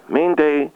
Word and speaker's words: Mainday: M-A-I-N-D-A-Y. – M-A-I-N? – No, M-A-I-N-D-A-Y. – M-A-I-N-D-A-Y. – Yes. – That's M-A-I, Main Day. – Yes, that's, her name is, yes, it's pronounced Main Day.